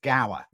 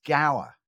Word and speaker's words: Gower: Just for clarification, my name is pronounced Gower.